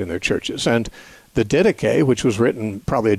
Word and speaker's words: Didache: Didache is spelled like the word didache, in a D-I-D-A-C-H-E. It's Didache.